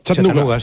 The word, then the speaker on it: Chattanooga: ¿Y se pronuncia así en Chattanooga? – Chattanooga, sí.